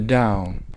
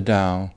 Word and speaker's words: Tao: The Chinese word that comes to us that you spell with in the old spelling, romanization, that T-A-O, that you pronounce with a D. Now it's also written with a D-A-O.